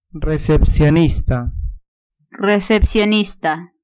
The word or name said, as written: recepcionista